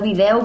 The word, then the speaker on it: vídeo: La vídeo vigilància. Doncs, en principi, la pronúncia en parlars oriental seria sense neutralitzar.